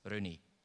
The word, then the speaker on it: René: Mit Betonung auf der ersten Silbe, das erste E als Ö ausgesprochen, wie es hierzulande üblich ist, René.